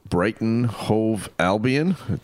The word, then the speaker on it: Brighton Hove Albion: I also got to see the Brighton Hove Albion. Did I say that correctly? – Perfectly correct.